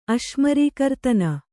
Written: ಅಶ್ಮರೀಕರ್ತನ

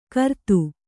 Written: ಘ್ರಾತ

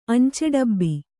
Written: ಅಂಚೆಡಬ್ಬ

